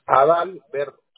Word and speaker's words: Adalberto: Es Adalberto.